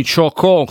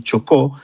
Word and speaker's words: Chocó: So che siete impegnatissimi, un team di circa 24 persone, se non sbaglio, opera nel dipartimento di Chocó, se si pronuncia così. Ci aiuti lei, Orlando, come si pronuncia e dove si trova questo dipartimento, che è stato l'epicentro un po' delle varie scosse di terremoto degli ultimi giorni? – Sì, esattamente. Allora,